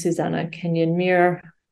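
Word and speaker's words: Susanna Kenyon-Muir: LinkedIn is Susanna Kenyon Muir.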